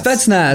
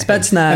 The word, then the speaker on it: Spetsnaz: Say it. Spetsnaz. – Is how you say it? – Spetsnaz.